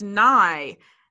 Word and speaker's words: Nighy: Yeah, no, so the only reason I know this is because I read a pronunciation guide that said it's pronounced Nye, and I was like, I've never heard anybody say that. And it's because it's pronounced Nye with a British accent. So the E kind of double bounces. It's not a silent E.